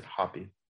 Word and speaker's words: Hopi: That's H-O-P-I, Hopi, or you might pronounce it Hoppy.